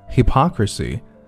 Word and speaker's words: hypocrisy: Richard Branson。Sir Richard Branson has been accused of hypocrisy, hypocrisy, 注意重音的位置在后。Hypocrisy. Sir Richard Branson has been accused of hypocrisy after investing in a luxury,